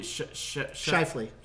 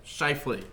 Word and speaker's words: Scheifele: How do you pronounce Scheifele? – Scheifele. – Scheifele. – Mark Scheifele.